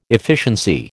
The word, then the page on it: efficiency: American English